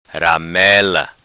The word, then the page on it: Ramela: patua